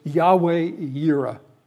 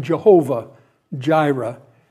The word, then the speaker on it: Yahweh Yireh: In Hebrew, it's Yahweh Yireh. And perhaps you've heard the expression Jehovah Jireh, which is not the right way to pronounce it, but it was familiar because of the King James Version.